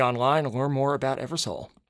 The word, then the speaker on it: EverSōl: That's EverSōl, EverSōl, like the sun. – Yeah. – Mm-hmm. – Pronounced soul with the long O.